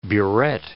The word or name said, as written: buret